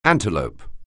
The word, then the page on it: antelope: anglais